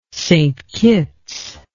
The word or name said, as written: St Kitts